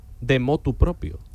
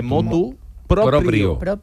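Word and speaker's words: motu proprio: No se dice de motu propio, se dice de motu propio.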